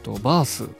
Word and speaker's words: BARTH: バースさんですかね、 BARTH と書いてバースと読むんだと思うんですけども、